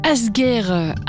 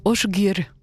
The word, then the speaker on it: Asgeir: Asgir, A-S-G-E-I-R, semblerait qu'en fait ça se prononce Osgir, un duo qui vient d'Islande entre Amy Margaret Seyfarsdóttir et Osgir Einarsson.